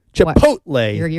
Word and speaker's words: Chipotle: Chipotle. – How should it be? – Chipotle. – You're adding extra syllables in there for some reason.